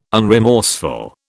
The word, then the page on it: unremorseful: British English